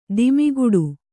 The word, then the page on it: ದಮ್ಮುಕಟ್ಟು: dammu kaṭṭu